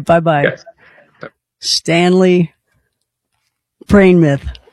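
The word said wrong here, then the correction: Praimnath: Praying Nith, let me say that right.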